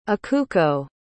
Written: ikuko

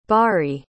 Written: baari

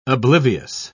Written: oblivious

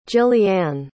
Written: jillianne

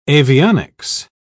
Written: avionics

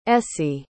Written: essie

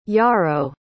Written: yaro

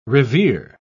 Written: revere

